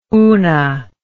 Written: una

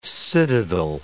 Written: citadel

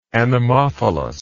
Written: anemophily

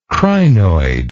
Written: crinoidal

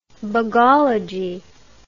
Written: bugology

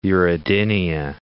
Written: uredinia

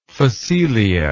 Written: phacelia

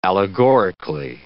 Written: allegorically

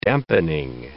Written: dampening

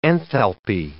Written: enthalpy

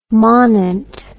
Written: manent

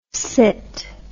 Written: cit

